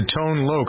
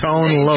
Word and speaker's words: Tone Loc: Number four during this week in 1989 belonged to Tone Loc. How'd I do, Jennifer? Is that right? – Very good. Yes. – Tone Loc.